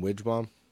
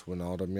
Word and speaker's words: Wijnaldum: But I was calling him Widgebom, just because that's what it looked like. I knew it was wrong, but I couldn't pronounce Wijnaldum yet.